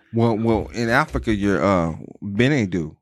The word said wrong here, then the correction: Chinedu: Yeah, Chinedu. – Chinedu. Yeah, no, I said it wrong.